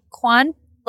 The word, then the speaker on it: Cuan: Laviers, A-L-A-V-I-E-R-S. – Katie and I'm just my first and last name, Katie Kwan, 9 letters. Kwan like is pronounced like Michelle Kwan, the figure skater. – Right. – But it's spelled C-U-A-N.